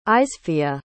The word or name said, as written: izefia